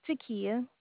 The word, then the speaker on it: Tkeyah: Takiya. – That's a pretty name. How do you spell that? – T-K-E-Y-A-H.